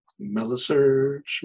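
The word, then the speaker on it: Meilisearch: But no, I think they said Melly, so like rhymes with smelly. – Alrighty, I'll take it. – Melisearch.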